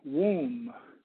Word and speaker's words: womb: The word womb, spelled W-O-M-B, the B is silent.